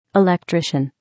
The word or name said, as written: electrician